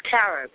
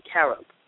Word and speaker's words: carob: I got a question, Dr. Nardi, about K-Rob. Yes or no? – About what? – It's Carob. It's spelled K-R-O-B. Carob. – K-R? – It's spelled C-A-R-O-B. – C-A-R-O-B.